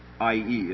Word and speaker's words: Ai: Ai, or I.E. as they pronounce it,